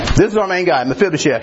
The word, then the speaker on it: Mephibosheth: How do you say this name? It's a big old biggin. So I did this. It's Mephibosheth. Mephibosheth. So let's say it together.